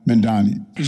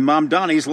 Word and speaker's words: Mamdani: Zoran Mamdani.